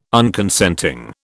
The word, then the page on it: Unconsenting: British English